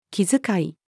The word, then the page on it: 気遣い: female